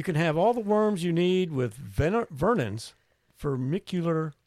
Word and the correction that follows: Vermiculture: Kit. Everything you... – Vermiculture Kit.